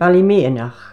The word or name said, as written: BALLYMEANACH